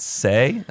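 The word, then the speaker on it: Seay: This is a golf course designed by Ed Say, I believe is how you pronounce it.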